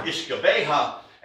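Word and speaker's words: uisce beatha: And then up into the Gaelic area where the Irish pronounced it as ishkabeha and the Scots pronounced it as ushkaba,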